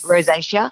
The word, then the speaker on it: rosacea: Yes, rosacea and things like.